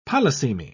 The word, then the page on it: polysemy: British English